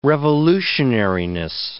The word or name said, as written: revolutionariness